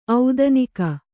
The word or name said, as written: ಔದನಿಕ